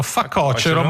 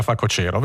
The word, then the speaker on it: facocero: Questa è una domanda interessante perché io credo che tutti dicano facocero, ma in realtà si dica facocero.